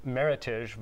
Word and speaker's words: Meritage: People confuse the pronunciation of Meritage versus Meritage, which is a blend of Bordeaux-style varieties from California, from Washington State. And it's a combination of the words merit and heritage. So it's actually, if you see a Meritage blend on a wine list, it's pronounced Meritage as opposed to Meritage.